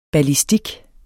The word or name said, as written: ballistik